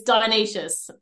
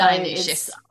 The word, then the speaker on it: Dionysius: Yeah, it's Dionysius. So Dionysius. It's Greek, apparently, so yeah.